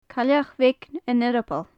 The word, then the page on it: cailleach bheag an earbaill: gaelic